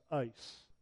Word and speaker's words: eis: The Greek word that appears for four in verse 38 is E-I-S. It's pronounced ice. E-I-S.